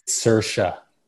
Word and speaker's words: Saoirse: You know, they're like, it's Saoirse, you know, not because it's spelled like so different.